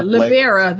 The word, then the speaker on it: Libera: Yeah, Libera, though, is his last name. – Libera. – L-I-B-E-R-A.